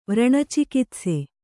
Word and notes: ವ್ರಣಚಿಕಿತ್ಸೆ: Kannada

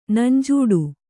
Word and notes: ನಂಜಿಡು: nanjiḍu

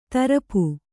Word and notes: ತರಪು: tarapu

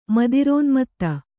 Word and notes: ಮದಿರೋನ್ಮತ್ತೆ: madirōnmatte

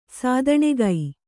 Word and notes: ಸ್ಥಾನಿಕ: kannada

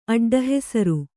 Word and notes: ಅಡ್ಡಹೆಸರು: aḍḍahesaru

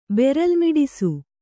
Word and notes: ಬೆರಲ್ಮಿಡಿಸು: Kannada

